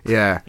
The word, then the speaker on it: Ye: Yeah. I think it's yay. – I believe that it is pronounced ye.